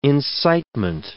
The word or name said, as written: incitement